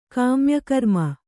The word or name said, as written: ಕಾಮ್ಯಕರ್ಮ